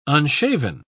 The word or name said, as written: unshaven